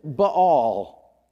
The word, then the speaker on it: Baal: He even mentions Baal. That's how you pronounce the B-A-A-Ls.